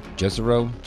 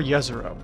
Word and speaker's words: Jezero: Jezero? – It's both Jezero or Yezero. There's two ways you can pronounce it.